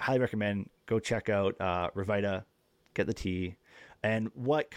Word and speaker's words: Rviita: Yeah, Revita Energy Tea, it's spelled R-V-I-I-T-A.